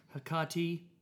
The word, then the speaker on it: Hecate: I've been calling it Hecate Enthroned for quite some time. Great question. Doing some research before the podcast. It is, in fact, Hecate.